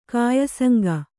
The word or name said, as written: ಕಾಯಸಂಗ